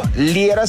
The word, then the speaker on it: Liera: Recuerda también en redes sociales, tú armas la ruta del amor, me encuentras a mí por mi nombre, Fernando Liera, se escribe L-I-E-R-A.